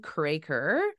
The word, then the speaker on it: Kroeker: And so I have Megan Kroeker.